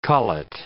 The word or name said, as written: collet